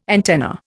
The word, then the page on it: antenna: Indian